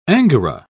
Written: angora

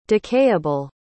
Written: decayable

